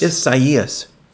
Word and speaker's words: Isaias: Well, it's four syllables. It's I think I'm saying it right. – Isaias? – Isaias. – I think that's about how I've heard it pronounced, yeah.